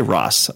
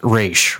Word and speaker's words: Ra's: I say Ross. I know everybody started the new pronunciation is Raish, right? – Well, I think they go back and forth. They call him call him Roz on the show, which to me just sounds like, wasn't there a character on Frasier named Roz?